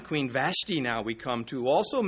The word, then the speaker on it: Vashti: But the pronunciation of Vashti, well, if you one prefers Vashti, another prefers Vashti. Well, I drink tea. So, and I wear ties. I can go with either one, but I prefer pronouncing it Vashti, and you should too.